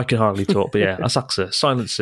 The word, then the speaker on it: Asakusa: I mean, the way to pronounce it, it's kind of a it's kind of silent. Yeah, it's like Asakusa.